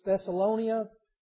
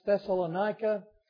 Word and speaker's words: Thessalonica: Now, it's sometimes it's pronounced Thessalonia, sometimes Thessalonica, and so I may say it just because I'll miss it either way. It's the same town.